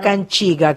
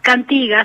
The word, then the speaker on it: cantiga: Tua Canchiga, que se escribe Cantiga. Cantiga, así es.